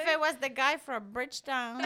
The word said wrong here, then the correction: Bridgerton: Bridgerton. Not from Bridgetown.